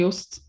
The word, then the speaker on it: Jost: And that's what the team principal, Jost, is that how you pronounce it? – I say Jost.